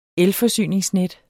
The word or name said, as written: elforsyningsnet